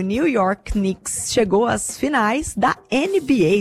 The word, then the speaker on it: Knicks: Rafa, tô atualizando aqui o nosso feed, a gente volta a falar de esporte, porque Knicks e Seleção Brasileira, eu falei certo? Knicks? – Falou certinho. – É assim que pronuncia? – Falou certinho, falou certinho.